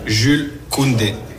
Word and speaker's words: Jules Koundé: Jules Koundé.